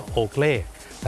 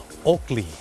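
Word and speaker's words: Oakley: คนไทยออกเสียงว่าโอเกลแต่เจ้าของภาษาไม่ได้ออกเสียงว่าโอเกลครับเขาออกเสียงว่าโอกลีโอกลี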